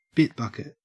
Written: bit bucket

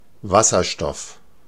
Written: Wasserstoff

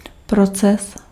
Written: process

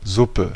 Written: broth

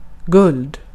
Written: altın

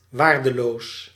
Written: worthless

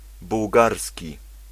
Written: bułgarski

